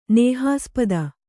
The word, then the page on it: ನೇಹಾಸ್ಪದ: nēhaspada